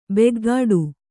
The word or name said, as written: ಬೆಗ್ಗಾಡು